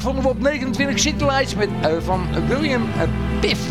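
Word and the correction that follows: Pitt: Ja, Piff zeg ik dat goed. Piff of Pit? Pit is het.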